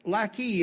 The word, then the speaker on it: Lachish: And so it says, when he came to Lachish, which, and this is, this Lachish is how it's pronounced, is not far from Zorah.